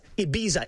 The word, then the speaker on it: Ibiza: So it's Ibiza. It's a Z. I say it like a Z, okay?